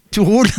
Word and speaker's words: Torul: Alors, je le prononce en anglaise, hein, puisque ce groupe chante en anglais, mais ils sont slovènes. Et ça s'écrit T-O-R-U-L. Et en slovène, ça se prononce comment ? – Tout roule.